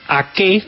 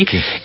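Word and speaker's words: Aké: Y Nathan Ake, así dicen que se pronuncia, en una aplicación se llama Forbo. Entonces usted se mete y dice, bueno, ¿cómo pronuncian este nombre en neerlandés? Entonces, así como usted lo pronunció, Nathan Ake, así. Ake.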